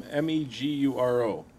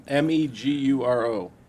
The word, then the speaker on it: Meguro: Kawasaki Aircraft initially manufactured motorcycles under the name MEGURO. – Wait, I'm sorry. Spell that again. – MEGURO.